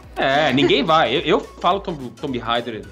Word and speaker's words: Tomb: E é um jogo gostoso que mistura elementos de Uncharted, que mistura elementos de Tomb Raiders antigos. Inclusive, eu sempre falava Tomb Raider quando eu era pequena. – É, e os produtores falaram que é Tomb Raider, é U que se pronuncia.